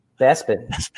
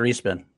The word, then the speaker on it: Bespin: Is it pronounced Bespin? It's Bespin, right? – Bespin. – It's Bespin. – Oh. – Not Respin.